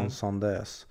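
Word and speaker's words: Incendies: Han hade gjort, eh, jag tror att den uttalas Ansandis, eller Ansandös.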